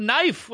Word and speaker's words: knife: Same with knife. What's that K in there? – I don't like a K.